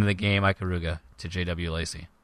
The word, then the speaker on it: Ikaruga: Ikaruga, I-K-A-R-U-G-A.